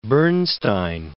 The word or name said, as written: Bernstein